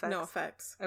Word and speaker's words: NOFX: NOFX.